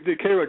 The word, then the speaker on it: Cairo: I live in, they call it Cairo, but it's actually spelled like Cairo.